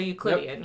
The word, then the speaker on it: Euclidean: I think I'm pronouncing that right because it's Euclid, I think. E-U-C-L-I-D. – Yeah. – So Euclidean, yep.